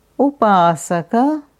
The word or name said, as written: Upasaka